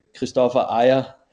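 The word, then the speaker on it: Kristoffer Ajer: Christopher Adja is someone. I'm sure I've pronounced that wrong. How would you pronounce that? Is Scandinavian? – Scandinavian, you would say Christopher Ayer.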